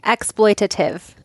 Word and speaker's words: exploitative: I highly suggest if you love like celebrity gossip, and not in the exploitive exploitative? Is that what am I saying? – Exploitative. – Thank you. Yes. I knew I was missing a syllable.